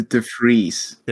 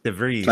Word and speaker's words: DeVries: It's the DeVries. – DeVries. – Like double E.